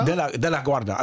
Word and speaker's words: De La Guarda: De La Guarda, also nicht ganz wie der Flughafen.